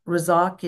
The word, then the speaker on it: Razak: Razak is, yeah, Razak. Yeah, it could even roll the R, ra. She says it, the native speaker, yeah, Razak.